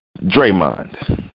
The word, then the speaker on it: Draymond: But Draymond, which rhymes with Raymond, why?